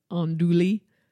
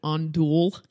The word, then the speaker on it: andouille: Reading this word, I always think it should be pronounced sort of like the French way, like andouille or andouille. – Andouille? – Yeah. Sausage? – Yeah. – Is that how you say it? – I think it's andouille.